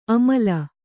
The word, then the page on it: ಅಮಲ: kannada